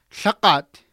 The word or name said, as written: lhaqat